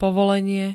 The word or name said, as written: povolenie